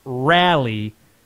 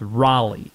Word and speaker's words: Raleigh: It's not rally, it's Raleigh. Raleigh is the proper pronunciation.